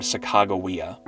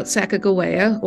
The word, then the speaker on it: Sacagawea: The wife the captains chose to go with them was named Sacagawea. – Now, what we know about Sacagawea, or Sacagawea, depending on which spelling and pronunciation you prefer,